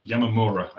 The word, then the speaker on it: Yamamura: So after this particular match, Ishida calls out Takahiro Yamiara. Yamamura, I should pronounce his name correctly.